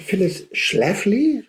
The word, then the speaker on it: Phyllis Schlafly: Phyllis Schlafly, habe ich das richtig ausgesprochen? – Ja.